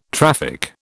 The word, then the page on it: traffic: British English